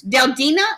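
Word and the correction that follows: Del Día: Is that how you say it? Dia? – Yeah. Well, let me read it off. The brand is called Del Dia or Del Dia the day.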